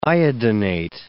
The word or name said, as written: iodinate